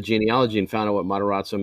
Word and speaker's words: Matarazzo: Heather Matarazzo. How do you say it? Heather Matarazzo.